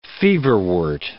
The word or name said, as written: feverwort